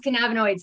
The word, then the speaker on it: cannabinoid: She's a retired RN, professor, diplomate of medical cannabinoid sciences. Did I pronounce that correctly? – We're close. It's cannabinoid sciences.